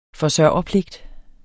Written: forsørgerpligt

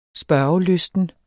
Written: spørgelysten

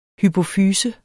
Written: hypofyse

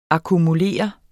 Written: akkumulere

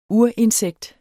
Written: urinsekt

